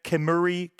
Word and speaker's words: Kemuri: Let's keep those positive tracks, those positive ska vibes rolling with the next band out of Japan, Kemuri, K-E-M-U-R-I. K-E-M-U-R-I. Okay, I did say that right.